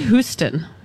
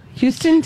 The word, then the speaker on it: Houston: Let's go to Houston. Houston, Texas. – It's Houston. – Sorry, Houston. So no, so we don't pronounce the H on that? – Not in Texas. You wouldn't say Houston.